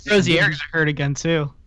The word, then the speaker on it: Rozier: First of all, I speak French and it's Rozier, so it's still only two syllables.